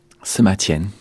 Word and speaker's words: Sima Qian: Vous le prononcez différemment, comment dites-vous ? – Sima Qian.